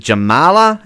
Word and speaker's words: Jamala: The song 1944 with, is it Jamala? Am I saying that correctly, Jarrod? I think that's right. – Yeah, I believe so.